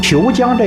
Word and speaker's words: 虬江: 虬江这个虬字实际上是上海话的旧的发音相近，是这么来的。